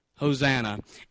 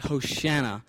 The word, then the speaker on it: Hosanna: It looks like it would sound different, but I'm going to stick with what I read. It's spelled a little different. That looks like Hoshana, but it's the Hebrew word, the same Hebrew word used in Psalms 118:25.